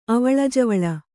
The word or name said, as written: ಅವಳಿಜವಳಿ